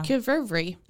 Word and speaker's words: qvevri: Uh, I'm gonna say it wrong again, but the qvevri. Mm-hmm. That's how that's how it's said. – That's how it, yeah qvevri. That's how it's almost exactly spelled too.